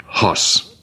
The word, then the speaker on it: hos: The relative pronoun, who, and there's some little marks on top that tell you to pronounce it hos, like H-O-S, hos, hos. The word for God is T-H-E-O-S.